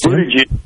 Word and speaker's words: Buttigieg: Yes, Buttigieg. – That nice job. I thought for sure he was going to do it. – Oh, man. – I did. I thought for... So, Buttigieg, Kamala Harris, your girl.